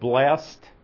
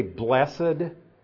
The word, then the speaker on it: blessed: Now, the reason I pronounce that blessed, remember we're studying the Beatitudes over in Matthew, and I said blessed, and now I say blessed, here is because it's a different word in the original text.